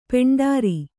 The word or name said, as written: ಪಾಂಡುರ